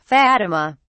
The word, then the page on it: fatima: British English